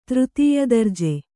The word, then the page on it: ತೃತೀಯದರ್ಜೆ: třtīya darje